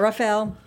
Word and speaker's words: Raphael: Want to put like an extra syllable in there, but Raphael, Henry, Colin Cange, Daniel Lugo. I did it good, right? – You did.